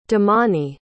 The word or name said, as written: damani